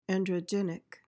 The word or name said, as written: androgynic